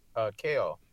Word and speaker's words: Kael: Kael.